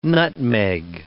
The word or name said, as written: nutmeg